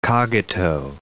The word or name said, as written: cogito